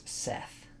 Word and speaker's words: Seth: It says the Egyptian god of chaos is Seth, is his name. – Set? Oh, wow. Seth. Yeah, Set and Seth. It's just different pronunciations, my understanding.